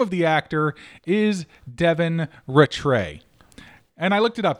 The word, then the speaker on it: Rattray: That's how you pronounce it, Rattray. – I'm so glad you said that, because I've been saying Rattray in my head all day. And I'm like, there's no way that that's last name. – It actually says it's pronounced Rattray.